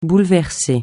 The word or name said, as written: bouleverser